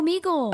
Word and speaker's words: Omegle: and I'm most likely going to butcher this, Omegle. – Oh, Omegle. – Omegle. – Yeah. – O-M-E-G-L-E.